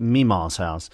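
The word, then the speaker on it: Mamaw's House: because she says you've been saying that song all wrong. And I said, well, what do you mean? It's spelled M-A-M-A-W-S House, Mama's House. And he says, yeah, but she says you should call it Meemaw's House.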